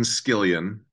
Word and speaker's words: Skillian: Well, my name is Devin Skillian, and it's a little bit of a mouthful. I tell people it rhymes with 7 million, but it's really interesting.